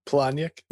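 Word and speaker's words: Palahniuk: Planyak.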